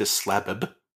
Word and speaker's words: Slabb: Because his name is spelt S-L-A-B-B. – Ah. – They pronounce the second B separately. – So ordinarily it would be Slab. – Yes. – But not in this case.